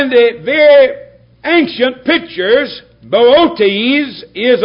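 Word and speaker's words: Boötes: And I had an awful time trying to call this word because I wanted to call it boots. But that's not what it is. I never saw a grammatical construction that has two long O's. And so what you have there is Bootes. Bootes is the way I would say it.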